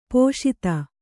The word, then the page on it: ಪೋಷಿತೆ: pōṣite